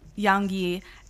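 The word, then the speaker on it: Yaongyi: I feel like I'm going to butcher this name, but Yanggi? – Yeah, it's exactly it. – Yes, mm-hmm. Yanggi.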